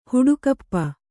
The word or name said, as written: ಹುಡುಕಪ್ಪ